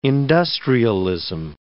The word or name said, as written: industrialism